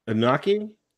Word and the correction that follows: Anunnaki: Hold on. A N N U N A K I. – Oh, Anunnaki. Anunnaki.